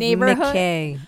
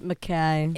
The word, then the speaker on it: McKay: But it's spelled like McKay. – Oh, okay. – But it's pronounced McKay.